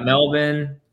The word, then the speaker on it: Melbourne: Got Melbourne.